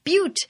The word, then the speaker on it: Butte: Butte. – Which is perfect for the butt-themed episode because it's spelled B-U-T-T-E.